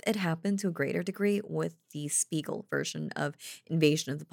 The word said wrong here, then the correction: Siegel: Sorry, I keep saying this guy's name wrong, like every time I say it in a different way. Siegel's version of Invasion of the Body Snatchers.